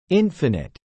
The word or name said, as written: infinite